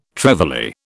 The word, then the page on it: trevally: British English